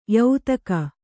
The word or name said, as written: ಯಾತಕ್ಕೆ